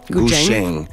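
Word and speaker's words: guzheng: Ça se prononce Gusheng. – Ouais, Gusheng. C'est plus un CH, un TCH, mettons.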